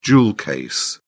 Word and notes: jewel case: British English

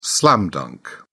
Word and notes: slam-dunk: British English

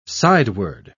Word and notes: sideward: British English

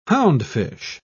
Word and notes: houndfish: British English

